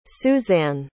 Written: Susann

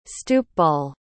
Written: stoopball

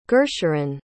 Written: gursharan

